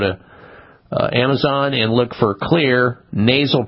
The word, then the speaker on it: Xlear: And so you need to keep your nose clean with a therapeutic nasal spray called Clear, X-L-E-A-R. That's how you spell it, X-L-E-A-R, nasal spray.